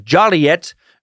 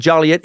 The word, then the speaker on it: Joliet: In Joliet, early residents, sick of hearing their town's name mispronounced as Joliet, made pronouncing it Joliet a misdemeanor, punishable by a $5 fine.